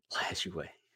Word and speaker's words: Lajoie: So it was It was him and was it Napoli Lazal? I don't know how you say his name. – Napoleon Lajoie. – There we go. Yeah. Lajoie.